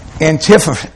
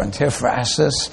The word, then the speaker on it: antiphrasis: The "I am not ashamed" is a figure of speech called an antiphrasis or antiphrasis. It's pronounced either way.